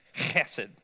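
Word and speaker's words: hesed (חֶסֶד): hesed, is you know, the Hebrew, they always have this letter that you got to get that thing going in the back of your throat. It's hesed, okay?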